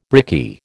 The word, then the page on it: brickie: American English